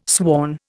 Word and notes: sworn: Indian

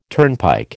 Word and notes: turnpike: American English

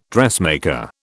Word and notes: dressmaker: British English

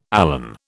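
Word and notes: élan: British English

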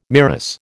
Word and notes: MIRAS: American English

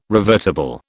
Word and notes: revertible: British English

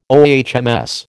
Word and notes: OHMS: American English

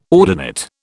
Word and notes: ordinate: British English

